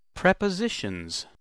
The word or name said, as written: prepositions